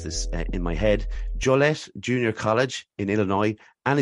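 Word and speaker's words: Joliet: I was doing that thing where the French wouldn't pronounce the T at one point, and I was like, Joliette. – No, we pretty much in America, we do say Joliette, and it's obviously named after the French explorer.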